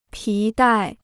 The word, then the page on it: 皮带: pí dài